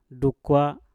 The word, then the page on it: dukwa: Mpur